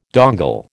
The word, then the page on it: dongle: American English